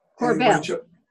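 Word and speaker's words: Korbel: Corbell.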